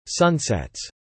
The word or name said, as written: sunsets